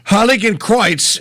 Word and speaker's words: Heiligenkreuz: I'm at Hochschule Heiligenkreuz. Good luck pronouncing that. It's a graduate school of theology in Heiligenkreuz. – Heiligenkreuz is Holy Cross. – You did it. Exactly.